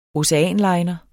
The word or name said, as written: oceanliner